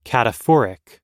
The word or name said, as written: cataphoric